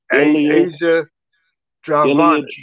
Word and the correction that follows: Ilya Dragunov: Ilya Dragunov.